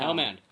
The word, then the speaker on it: Helmand: Helmand. Pronounce it right, man.